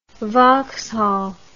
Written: Vauxhall

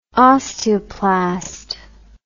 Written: osteoplast

